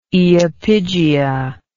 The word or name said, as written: iapigia